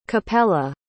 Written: Capella

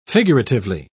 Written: figuratively

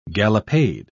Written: gallopade

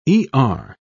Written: er

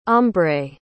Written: ombré

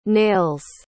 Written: nails

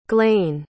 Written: glain

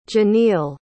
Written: Jeneal

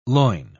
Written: loin

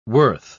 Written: worth

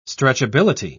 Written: stretchability

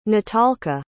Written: natalka

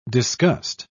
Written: disgust

